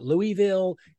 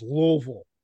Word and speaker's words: Louisville: No, it's not Louisville. It's not Louisville. It's Louisville. It's almost one syllable, really.